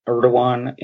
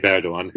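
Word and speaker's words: Erdoğan: Well, can you sort of talk about Erdogan and sort of what role he's had in the change? – Well, you did pronounce it reasonably correctly. Recep Tayyip Erdogan,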